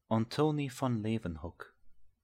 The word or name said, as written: Leeuwenhoek